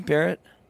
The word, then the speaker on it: Barrett: Is that how you say your last name, Barrett? – Yes, sir.